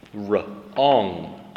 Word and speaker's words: wrong: King, king, 国王 ring, ring, ring, ring, 戒指 wrong, wrong, 那个 W 不发音，W 在一起，WR 在一起的时候，W 不发音，wrong, wrong, 错误的。